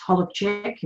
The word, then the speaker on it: Holobczyk: I was born Fiona Ray Holozak, or it's pronounced Holobczyk in Polish, on the 28th of October, 1969, in Sydney, Australia.